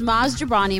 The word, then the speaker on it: Jobrani: My guest this week is Maz Jobrani. Am I saying it right? – You nailed it.